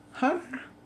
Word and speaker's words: Hunter: Miss Gina Hunter.